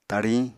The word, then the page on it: tariñ: Apatani